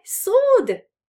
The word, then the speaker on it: sword: And in January or February, I got my sword back, yeah? Sword, miecz, remember pronunciation, we don't read the W, which is there in spelling. So not, it's not a sword, it's a sword.